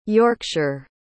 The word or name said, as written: yorkshire